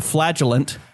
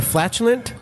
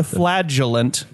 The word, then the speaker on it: flagellant: They actually added a new hero to the game, the Flagellant. – Excuse me. Hold up. The flatulent? – No, not the flatulent. The flagellant. Soft flagellant. – Soft G. As in they flail themselves.